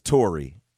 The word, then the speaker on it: Tory: No, it's Tori. – Tori Hart. – Yeah, it's Tori Hart, yeah. – Look, Evansville Public School. You know what I'm saying? Like, if you don't spell it T-O-R-Y, you got to give me a second.